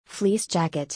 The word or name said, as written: fleece jacket